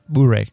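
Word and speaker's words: Bouré: Snack Bar, Oxford Boar. Am I saying that right? Bure. Thank you.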